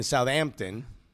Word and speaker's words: Southampton: You don't pronounce the H. Not you. You're not supposed to in the Royal U. – West Ham? – No. You're in the in Southampton. – Southampton.